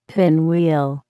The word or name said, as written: pinwheel